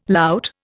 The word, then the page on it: lout: Indian